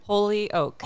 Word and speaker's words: Holyoke: Holyoke.